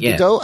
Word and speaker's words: Gadot: Is it Gadot? – Gadot.